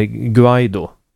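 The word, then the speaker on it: Guaidó: Det ska uttalas med ett hårt G. Som är Guaido. – Ja. – Och ungefär, diftongen låter ungefär så. Och det är lite, folk uttalar olika. Vissa betonar första stavelsen, andra betonar andra stavelsen.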